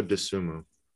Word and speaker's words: Dosunmu: Dosunmu, yeah. That's pretty high. What? – Is that how his last name is pronounced? – Yeah, Ayo Dosunmu.